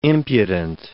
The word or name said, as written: impudent